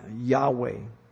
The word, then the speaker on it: Yahweh: The tetragrammaton, YHWH, because there's no vowels. We believe it's pronounced Yahweh.